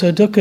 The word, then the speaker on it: dukkha: Pali again being the language of the Buddha's teachings, and that word is dukkha. It's spelled D-U-K-K-H-A, dukkha.